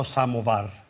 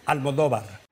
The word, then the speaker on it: Almodóvar: In Italy they call me Aldo Samovar. So anyway, if you want to pronounce it Pedro Almodóvar.